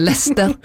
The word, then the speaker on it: Leicester: Kjekt å høre på, men det heter Leicester.